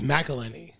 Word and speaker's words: McElhenney: Because it's MC, then a vowel, it's McElhenney.